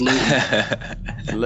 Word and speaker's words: Luton: I do still pronounce Luton appropriately. I dropped the T in the middle. It's Luton.